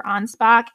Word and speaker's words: Anspach: And that's spelled A-N-S-P-A-C-H, but I looked it up and it says it's Anspach, so.